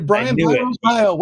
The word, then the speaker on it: Brian Biro: Brian Biro.